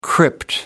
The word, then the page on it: crypt: American English